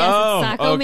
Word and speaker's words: Saco: We also have Saco, Maine. I hope I pronounced that correctly. – That's right. I used to live up there. Yes, Saco, Maine.